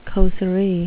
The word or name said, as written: causerie